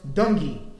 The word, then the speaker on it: Dungy: There's Tony, I think his last name is pronounced Dungy.